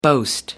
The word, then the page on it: boast: American English